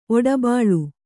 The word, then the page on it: ಓಡುಬಿಲ: ōḍubila